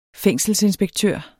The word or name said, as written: fængselsinspektør